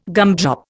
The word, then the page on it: gumdrop: Indian